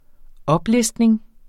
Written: oplistning

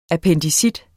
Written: appendicitis

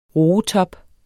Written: roetop

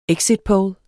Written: exitpoll

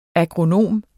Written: agronom

